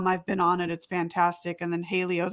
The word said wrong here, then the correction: Halo: Is that how you pronounce it? – Halo? – Yeah. Halo. I don't know why I kept saying Haleo.